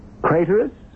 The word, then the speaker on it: Craterus: Craterus? Which rhymes with traitorous.